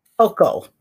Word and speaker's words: Hochul: Hochul, H-O-C-H-U-L, Hochul, Kathy Hochul.